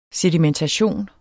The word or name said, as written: sedimentation